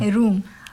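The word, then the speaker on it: room: روم. روم از حرفوی از دبل او در داخل این جمله استفاده شده است، ولی او تلفظ می شود اوی دراز روم.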